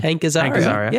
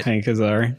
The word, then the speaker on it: Hank Azaria: How do you say his name? – Hank Azaria. Yeah, you got that one right. – Hank Azaria.